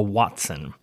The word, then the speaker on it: hoatzin: The coolest was a prehistoric bird called the Watson, and that's spelled H-O-A-T-Z-I-N, but pronounced Watson.